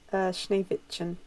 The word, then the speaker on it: Schneewittchen: Is that how you pronounce it? – Schneewittchen. Yeah. – Schneewittchen.